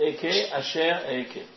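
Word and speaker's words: Eke, Asher, Eke (אהיה אשר אהיה): C'est-à-dire, en fait, ce que tu vas leur dire, c'est ce nom divin-là, qui est, comme c'est un nom divin, je ne le prononce pas comme il s'écrit, c'est Eke, Asher, Eke.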